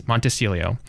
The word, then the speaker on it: Montecillo: But the interesting thing is my last name is Montecillo, and is always mispronounced as Monticello. Always. My entire life. And that little switch of the I-N-E has meant such a huge difference.